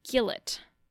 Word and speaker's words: gilet: Okay, now how would you expect to spell that, dear North Americans? Maybe G-E-U-L-A-E-G-H? Maybe with a J even? I had to have a friend spell that for me the first time I heard it, because, well, it's not what you think it would be. It's G-I-L-E-T, like a gillet.